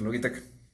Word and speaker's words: Logitech: Bueno, en realidad creo que la lucha fue un poco dispareja porque luchó contra los dispositivos Logitech, si es que así se pronuncia. – Así es, así se pronuncia Logitech.